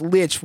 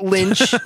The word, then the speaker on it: lich: And I specifically said it's pronounced lich, which rhymes with ditch, and that we have nothing to do with Warcraft or Adventure Time.